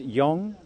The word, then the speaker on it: Yong: It's Ed Yong, so it rhymes with song, and I'm a science writer at The Atlantic.